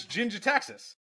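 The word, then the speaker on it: Jin-Gitaxias: Gin gin get taxes or gin did taxes? – What? How do you say pro- it? – it? You get probe somebody, but it's Jin taxes.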